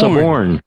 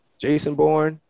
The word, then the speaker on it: suborn: Suborn. – Suborn. Suborn. Not Jason or somebody else born. Jason born.